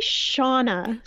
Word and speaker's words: Shauna: Oh, now we always joke about the way I say your name with my accent. I was like, Shana. How do you say it? – I say Shauna. So Shauna. So you put a little Shauna. Shauna in it. And I should say Shauna. I can't do it.